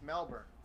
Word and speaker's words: Melbourne: Or is it Burn? Is it Burn where you don't pronounce the O? – Correct. – Really? – Melbourne. – That's how they pronounce it? – It's Melbourne.